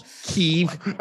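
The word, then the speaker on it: Kyiv: The capital city of Ukraine is has always been known it pronounced in the Western language as Kiev, Kiev, specifically two that two a, a, very specific turning in the middle, Kiev. Now all we hear is Kiev, Kiev, Kiev.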